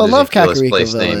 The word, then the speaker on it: Kakariko: Kakariko. – I think that's right. – Kakariko.